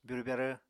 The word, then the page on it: byurw-byarw: apatani